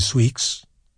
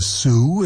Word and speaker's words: Sioux: Now, the fact is that he didn't really think highly of either the Sioux, or we don't say Sweeks, it's the Sioux, it's a like French, right? Everything in that area first discovered by the French, and so given the French pronunciation, Michigan, Chicago, the Sioux, all right, the Sioux and the Cheyenne.